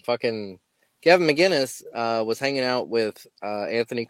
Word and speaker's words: McInnes: But yeah, I would like to understand his, you know, his relationship with Gavin McInnes, like McCain. I don't know how to pronounce that. – McInnes. – McInnes, sorry, Gavin McInnes.